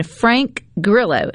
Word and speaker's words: Grillo: and then Frank Grillo, am I saying that correctly, Rhino? – That's correct.